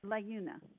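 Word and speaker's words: Laeuna: Layuna.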